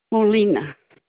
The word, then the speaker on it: Molina: It's Montre Molina.